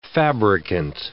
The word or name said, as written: fabricant